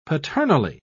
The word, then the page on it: paternally: British English